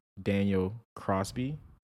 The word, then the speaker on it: Daniel Crosby: Welcome back to the second part of Laws of Wealth by Daniel Crosby. Did I say that right? – That was right, yeah.